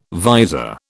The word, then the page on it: visor: British English